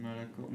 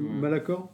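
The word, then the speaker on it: Malachor: Quelques années plus tard, vu qu'il a fait profil bas, il part en quête de savoir sur euh, Melacor, je sais pas si je le dis bien, ou Melachor. – Malacor, moi je... – Malacor ouais,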